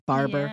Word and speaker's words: Barbour: Am I saying her name right? Barbour. – Yeah, Barbour. I was going to say Miranda Barbour. – It looks like, I don't know. – Yeah, it's like that extra U, you know.